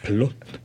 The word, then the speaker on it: Plot: Plot avec un T muet.